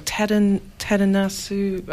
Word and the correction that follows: Tadanobu Asano: I know the actor I'm talking about. – He's in a lot of great stuff. Tadanobu Asano. – Yeah. That's him. Yes.